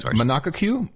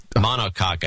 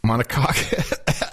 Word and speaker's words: monocoque: How do you pronounce that? Monaco Q? Monaco Q? – I don't know how it's pronounced. Monocoque, I thought. – Monocoque. – That's what I thought.